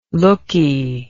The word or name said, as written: looky